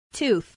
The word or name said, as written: tooth